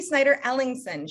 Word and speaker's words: Snyder-Ellingson: This is Lynsi Snyder-Ellingson. Did I say your last name right, Lynsi? – Yes. – Okay. So this is Lynsi Snyder-Ellingson.